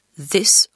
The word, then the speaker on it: this: Les pronoms démonstratifs diffèrent « this » au singulier, qui se prononce avec un « i » court et un son final « s » et « these » au pluriel,